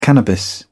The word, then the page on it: cannabis: British English